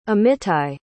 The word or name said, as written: amittai